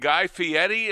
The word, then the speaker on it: Guy Fieri: Last night, Guy Fieri, is that how you pronounce his last name, Tony? – Yes.